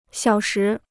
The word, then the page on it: 小时: xiǎo shí